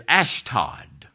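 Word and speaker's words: Ashdod: Notice it says he went down to, it's spelled A-S-O-T-U-S. It's actually pronounced in the Old Testament as Ashtod.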